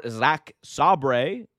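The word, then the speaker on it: Zack Sabre: Moving on, we were all wondering who the mystery opponent for Zach Sabre, yes, that's how I say his name, Zach Sabre Jr., was going to be in replacing Brian Danielson, and we found out that it was, in fact, Claudio Castagnoli.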